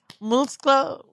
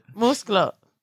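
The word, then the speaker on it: muslo: And then I learned chicken thigh is musclo. What's that? Name? – Look at you, see? – I want, no, what is it? – Musclo. Pollo musclo. – You're saying it right.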